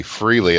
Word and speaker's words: Frehley: freely, and it's like uh, Haley's comet rhymes with Frehley's Comet. It's like, oh, so Ace Freely, right?